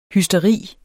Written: hysteri

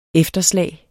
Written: efterslag

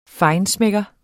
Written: FEINSCHMECKERE

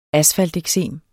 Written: asfalteksem

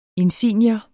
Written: insignier

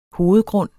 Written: hovedgrund

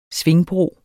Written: svingbro

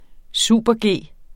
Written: super-G

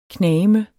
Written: knageme

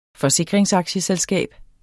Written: forsikringsaktieselskab

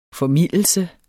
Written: formildelse

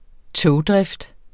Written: togdrift

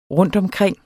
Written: rundtomkring

